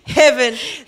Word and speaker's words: Hibben: Hibben.